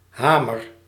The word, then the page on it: hamer: /ˈɦaː.mər/